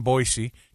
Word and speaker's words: Boise: We're going you get off the plane in Boise, take your rental car up there to Meridian. It's beautiful. – It's Boise. – Boise. – Got to hit the Z.